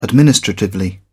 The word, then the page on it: administratively: British English